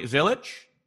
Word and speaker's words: Zilisch: Today we are here with Cory Zilisch.